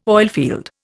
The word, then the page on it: oilfield: Indian